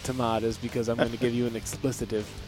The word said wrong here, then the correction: expletive: No, expletive.